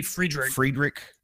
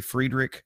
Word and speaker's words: Friedrich: Also, Gary, I might say this guy's last name wrong. Gary Fredreich passed away as well. He was. – It might be Friedrich. – Gary Friedrich. He was a co-creator of Ghost Rider, which is really cool.